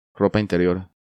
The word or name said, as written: Ropa interior